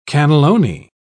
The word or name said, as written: cannelloni